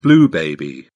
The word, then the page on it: blue baby: British English